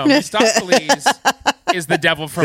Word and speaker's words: Mephistopheles: That is the way it should have been, but it's Mephistopheles. There's extra syllables in here, they say. They slam together.